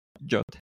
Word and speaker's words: JWT: Que según el estándar MFC no se pronuncia así, se pronuncia JOT.